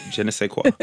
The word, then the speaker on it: je ne sais quoi: Je ne sais quoi.